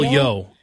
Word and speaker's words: Yeoh: And, of course, Michelle, is her name pronounced Yeoh? – Michelle Yeoh.